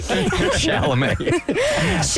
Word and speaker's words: Chalamet: First of all, we need to say his name right. – Chalamet.